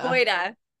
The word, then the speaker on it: Boyda: Is it Bonnie Boyda or Boyda? – Boyda.